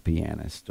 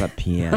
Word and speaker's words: pianist: That's not how you say it. – Yeah. – Pianist. – Okay. – Not a pianist.